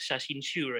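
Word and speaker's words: shashinshu (写真集): There is something that crossed my mind says that all this time we've seen in Japanese how they pronounce it as shashinshu, right? – Shashinshu.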